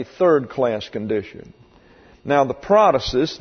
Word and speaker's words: protasis: P-R-O-T-A-S-I-S. Just exactly as it's spelled in the English with the accent on the first syllable. Prothesis, which means literally that which is put forward.